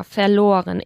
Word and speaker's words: verloren: Ich habe es irgendwo verloren, ¿vale? Tenemos aquí el participio de verlieren, ¿vale? – Que uh es verloren y que pronunciamos con una O larga, verloren.